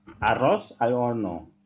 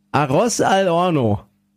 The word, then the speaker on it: Arroz al horno: Arroz al horno. – Arroz al horno.